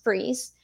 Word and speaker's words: Fries: It's spelled out as Fries, but it's pronounced Freeze.